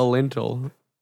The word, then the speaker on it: lintel: L-I-N. It's like Intel with an L in front of it. It literally, that's what it is. – A lintel.